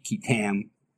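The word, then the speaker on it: qui tam: QI-TAM, which can be, if you look on the internet, is pronounced several different ways, but essentially the most common appears to be QI-TAM, as in it rhymes with Sam,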